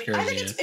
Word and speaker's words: Garizia: Now I'm questioning if it's Garizia. I'm pretty sure it's Garizia. – It probably is because Galen is a hard G too. – Yeah, okay. – Like all of the G's are hard.